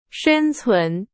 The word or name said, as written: 生存